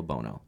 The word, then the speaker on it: Bono: His name is Angelo Bono. All right? And it's spelt like Bueno, but it's pronounced Bono. It's Italian.